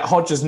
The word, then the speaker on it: Hoxha: Hoxha, is that how you pronounce it? Yeah.